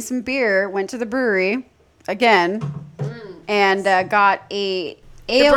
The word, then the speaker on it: Bruery: So basically, there is a place called the brewery. But it is spelled B-R-U-E-R-Y. So it's a fancy way of spelling brewery.